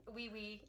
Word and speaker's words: Oui-Oui: Wee wee. The wee wee. – I call it the wee wee. But it is spelled O-U-I-O-U-I.